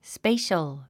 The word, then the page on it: spatial: spéiʃəl; スペイショゥ